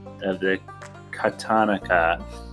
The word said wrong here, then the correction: katakana: or katana, kat, katakana, katakana.